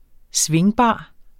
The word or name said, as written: svingbar